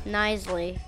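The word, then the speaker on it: Knisely: Nisley. – Nisley. – It's a silent K, yeah.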